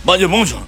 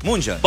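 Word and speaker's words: München: Ya, ¿es Bayern Múnich o Bayern Munches? – Bayern Munchen. – Munches.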